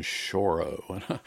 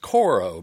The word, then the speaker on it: choro: I thought it was pronounced coro because, you know, when I was in high school, there was a chorus. We pronounce it chorus.